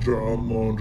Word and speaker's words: John Lone: Oh, I keep pronouncing that wrong. It's spelled John Lone just like the word lone.